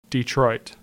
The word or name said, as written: Detroit